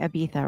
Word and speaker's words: Ibiza: So you're in Ibiza, I don't know if I'm saying that right, Ibiza, right? – Yeah.